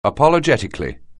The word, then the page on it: apologetically: anglais